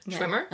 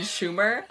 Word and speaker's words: Schwimmer: Schwimmer? – You said it right. – Oh my god. Informed me, like, yesterday that I've literally said David Schwimmer's name wrong every single episode of this podcast. So you've been calling him David Schumer